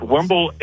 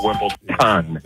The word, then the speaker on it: Wimbledon: It's Wimbledon. It's Wimbledon with a D, yes. Not yes. Wimbledon. – Thank you. I didn't want to be the person Wimbledon.